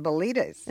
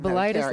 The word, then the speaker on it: Belitas: Well, that's why we pronounced it Belitas. – Instead of Belitis, like phlebitis? – Instead of Belitis, because we didn't want it to sound like a disease.